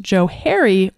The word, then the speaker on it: Johari: And it actually is pronounced Johari window because it was two names put together, Joe and Harry put together.